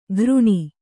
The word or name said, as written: ಘೃಣಿ